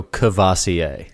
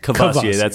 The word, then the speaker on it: Courvoisier: I lose that first R, and I go Courvoisier. – Courvoisier, that's what I know it as, too.